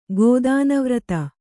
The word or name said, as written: ಗೋದಾನವ್ರತ